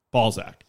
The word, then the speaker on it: Balzac: B-A-L-Z-A-C. Balzac.